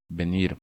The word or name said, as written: Venir